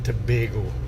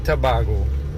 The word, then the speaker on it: Tobago: Just to correct you on the pronunciation, it's Trinidad and Tobago, not Tobago.